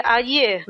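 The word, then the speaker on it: adjö: A grafia é totalmente diferente do francês, é a, deixa eu ver, a D J O com o trema. – Isso. E aí você pronuncia adieu mesmo.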